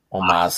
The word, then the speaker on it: Omos: I mean, Lashley and Amos, is that how you say his name? – Omos, sorry.